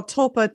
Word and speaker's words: tulpa: Is it tulpa, tulpa? – Yeah, well, tulpa, T-U-L-P-A, tulpa.